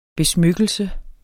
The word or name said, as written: besmykkelse